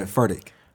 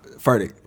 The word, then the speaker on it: Furtick: Pronounce his last name again? – Yeah, Furtick. – Hey, on everything. On everything. I had not known how to pronounce this dude's name. – Yeah. – Thank you. One more time. – Furtick.